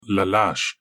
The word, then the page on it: llash: Galindri